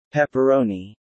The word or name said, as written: pepperoni